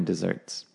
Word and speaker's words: dessert: Anyway, so yeah, the double S is pronounced like a Z or like a Z, so dessert.